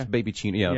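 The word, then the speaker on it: babycino: Am I saying that right? I guess that's right. – Yeah, I'm sure it's Baby Chino. Yeah, like cappuccino.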